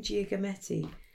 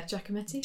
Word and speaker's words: Giacometti: Or I might try and do a kind of male figure but quite a kind of Gia Gia how do you say his name? Giacometti. Oh yeah, Giacometti.